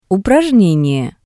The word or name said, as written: uprajnenie